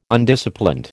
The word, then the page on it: Undisciplined: American English